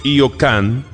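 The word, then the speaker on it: Hyo Kang: Es un conjunto que bajo la dirección de Io Kang, se escribe H-Y-O, Kang como suena con G al final,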